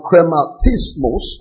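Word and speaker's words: chrematismos: Is the word krematismos, I'll spell it, C-H-R-E-M-A-T-I-S-M-O-S, C-H-R-E-M-A-T-I-S-M-O-S.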